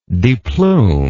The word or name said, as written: deplume